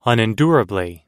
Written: unendurably

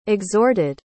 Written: exhorted

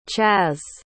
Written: chaz

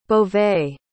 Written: beauvais

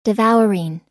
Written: devouring

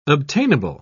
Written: obtainable

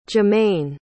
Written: jermaine

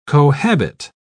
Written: cohabit

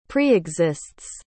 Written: preexists

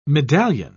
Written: medallion

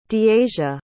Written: deasia

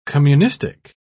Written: communistic

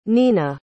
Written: nina

